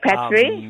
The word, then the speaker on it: Patcharee: Patchery. – Amazing. – Oh, Patchery. That's gorgeous. – So P-A-T-C-H-A-R-E-E.